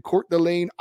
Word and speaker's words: Coeur d'Alene: He is in Coeur d'Alene, Idaho.